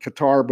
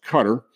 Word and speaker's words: Qatar: Now, in the meantime, also going on over in the country of Qatar, or as some people pronounce it, Qatar, but it actually should be pronounced Qatar.